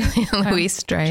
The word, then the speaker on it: Julia Louis-Dreyfus: Yeah, I mean, Julia Louis-Dreyfus. Did I pronounce that correctly? – Julia Louis-Dreyfus.